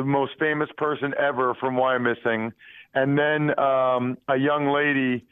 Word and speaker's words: Wyomissing: I'm glad you asked. It's Wyomissing.